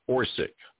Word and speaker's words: Orsic: Maria Orsic, O-R-S-I-C. I'm pretty sure that's how she pronounces her name.